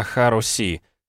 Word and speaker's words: Харуси: «Говорит Багет, ну что ты выставился, ты же не трахаться сюда пришел!» Вообще-то комроты Амер, позывной Багет, сказал не русский, а Харуси, с ударениями на первом и последнем слогах.